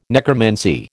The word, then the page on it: necromancy: American English